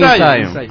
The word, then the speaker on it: In Zion: Es que el manager me dijo a mí que se llamaban Inción. – Ah, ya. – Él pronuncia como escribe, Inción.